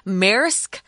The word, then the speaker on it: Maersk: Uh, this one's called the Maersk Etienne, or uh, Tom had a better pronunciation for this one. He said Marsk.